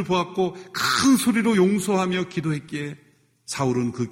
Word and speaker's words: 사울: 사울은 히브리식 발음이고 바울은 헬라식 발음입니다.